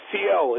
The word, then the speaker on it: Fiel: Say your name for us. – Fiel. It's spelled F-I-E-L.